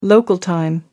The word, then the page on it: local time: American English